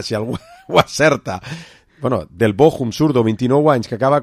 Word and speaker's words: Bochum: creo que se pronuncia Bochum.